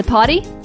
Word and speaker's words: Poddy: It's a potty. It is freedom from now. – Say it right, okay? Just like you have to say dunk, not dunk. Potty. – It's the potty. P-O-D-D-Y forever.